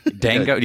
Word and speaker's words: Django: Django? You don't pronounce the J? – It's Django Unchained.